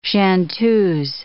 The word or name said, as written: chanteuses